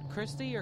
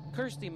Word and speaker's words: Kirsty: And that last track that Sean picked out, Christy or Kirsty? – Kirsty McCall.